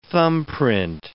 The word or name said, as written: thumbprint